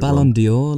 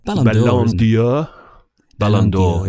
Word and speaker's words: Ballon d'Or: Didn't you call it Ballon d'Or? Like it was a sort of French like a fragrance. – Perfume. – Ballon d'Or, indeed. – Ballon d'Or.